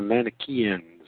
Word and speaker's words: Manichaean: Instead of assuming that we know what we're talking about, well, how to pronounce Mechanian? Mechanian? Mechanian. Nope. Manichaean. Manichaean.